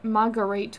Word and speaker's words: Marguerite: During this time, OJ married Marguerite Whitley, and they had three... – Marguerite? Are you sure it's how you pronounce that? – Yep. – Okay. Sure it's not Margaret? – No, spelled differently.